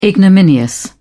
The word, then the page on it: ignominious: British English